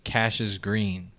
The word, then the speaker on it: Cassius Green: It's spelled C-A-S-S-I-U-S. Yeah, Cassius, but it's Cassius Green.